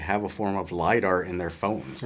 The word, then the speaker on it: LIDAR: How do you pronounce it? – LIDAR. – LIDAR, okay.